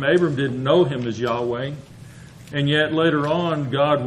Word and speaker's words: Yahweh: The letters are YHWH in our, if we were transliterating, translated in the American Standard Version as the word Jehovah, pronounced among the those that practice or call themselves Jews, Yahweh.